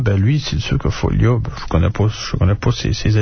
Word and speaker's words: Foglia: Foglia, faut dire. Foglia. Les Italiens ne prononcent pas. Le G est muet devant le L, comme bottiglia.